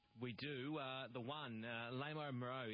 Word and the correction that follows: Les Amoureux: Actually, I'll start again there. Les Amoureux, is that how we pronounce it, gentlemen? The one? – Les Amoureux.